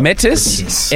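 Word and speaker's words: Métis: Well, I love the Matisse thing. Am I saying that right? – What are you talking about? – Metis? M-E-T-I-S.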